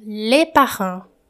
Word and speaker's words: les parents: Você vai saber se é plural ou se é singular observando a pronúncia do artigo le, nesse caso. Le parent, singular. Les parents, plural.